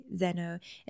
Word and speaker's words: xeno: I'm Greek, so xeno is how you say xeno.